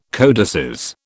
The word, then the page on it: codices: British English